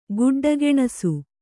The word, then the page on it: ಗೆಡ್ಡೆಗೆಣಸು: geḍḍe geṇasu